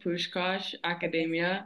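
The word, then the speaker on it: Puskás Akadémia: yeah, so it's called Pushkash Academia.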